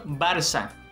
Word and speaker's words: Barça: Y lo segundo que queríamos recordar es que esa cedilla se pronuncia como S, en este caso Barça.